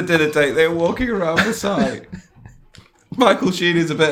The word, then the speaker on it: Versailles: At least say it right. Versailles. There's Versailles. – No... – Versailles. Ver fucking sigh.